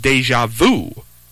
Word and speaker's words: déjà vu: Déjà vu, not the vu, which your teacher would say to you, round your lips for O, but pronounce E, and you will get E, the French U.